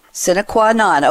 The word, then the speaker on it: Sine Qua Non: How did you pronounce it? – Sine qua non. – Sine qua non.